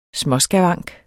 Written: småskavank

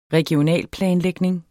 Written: REGIONALPLANEN

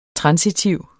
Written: transitiv

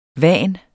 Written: van

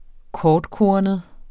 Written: kortkornet

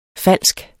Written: falsk

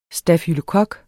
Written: stafylokok

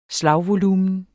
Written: slagvolumen

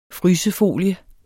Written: frysefolie

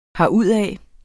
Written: herudad

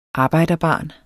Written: arbejderbarn

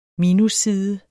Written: MINUSSIDE

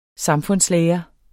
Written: samfundslære

